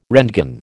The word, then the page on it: roentgen: American English